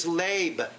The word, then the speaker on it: leb: The Greek, the Hebrew word is leb, L-E-B, long E, leb.